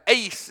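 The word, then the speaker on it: Ace (εἰς): It's the word for for, F-O-R. Ace, it would be kind of how you pronounce it in the Greek.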